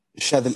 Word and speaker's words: Shathil: Est-ce que je prononce bien ton prénom C'est Chavil? – Chavil, exactement, exactement. Tu l'as très bien prononcé.